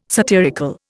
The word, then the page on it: satirical: Indian